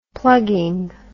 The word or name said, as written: plugging